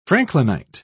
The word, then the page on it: franklinite: British English